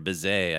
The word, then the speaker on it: Bizet: Bizet, or Bizet, I guess, to use the French pronunciation.